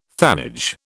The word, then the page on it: thanage: British English